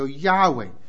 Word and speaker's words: Yahweh: 耶和华神，他们的希伯来的名字，他们怎么读呢？就叫 Yahweh。